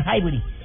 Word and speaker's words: Highbury: No, señor, se, se pronuncia Highbury.